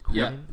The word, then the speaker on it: Quinnen: Quinnen? – Yeah, yep.